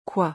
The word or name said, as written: quoi